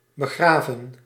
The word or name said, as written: begraven